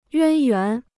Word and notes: 渊源: yuān yuán

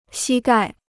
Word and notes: 膝盖: xī gài

